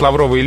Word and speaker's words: лавровый: А вот лавровый лист, лавровый венок – там ударение падает на «о».